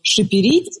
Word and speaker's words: шиперить: она произнесла его «шиперить». Я действительно потом посмотрела, он, правда, произносится скорее как «шиперить».